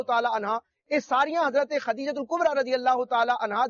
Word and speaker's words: خدیجہ: Normally خدیجہ کہتے ہیں لوگ, تا کے ساتھ پڑھ رہے ہوتے ہیں, لیکن ہم جب کتابیں پڑھتے ہیں تو یہ دال کے ساتھ ہے, خدیجہ, حضرت خدیجہ کبرہ.